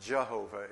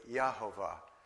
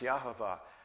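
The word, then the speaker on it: Yahweh: His actual name is, in Hebrew, it's four consonants, Yod, He, Vov, He, pronounced not Jehovah, it's really Yahovah. There is no J in Hebrew. But his name would be pronounced Yahovah.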